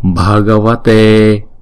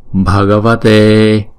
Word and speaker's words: Bhagavate: Namo Bhagavate Bhagavate. Chữ B và chữ H ghép lại thì chúng ta đọc là B B. Đó. Còn chữ E là diphthong cho nên chúng ta đọc kéo dài hai nhịp.